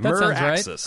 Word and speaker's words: Meraxes: M-E-R-A-X-E-S. How would you pronounce that? Miraxes? – Yeah, Miraxes.